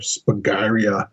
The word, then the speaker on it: Spagyria: could not pronounce Spagyria or Spagyria, as it's said in Europe.